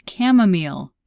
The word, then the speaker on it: chamomile: I played this game with my sisters this weekend, and one of the words that they used was chamomile. – Oh, wow. – And it was so hard because you got to C-H-A, and you don't think of C-H as being a hard C sound, so it took a long time for people to guess that.